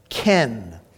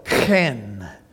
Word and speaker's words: chen: Now, in Hebrew, the word for grace or favor is ken. And it sounds like the name Ken, but it's actually spelled C-H-E-N.